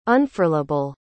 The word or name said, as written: unfurlable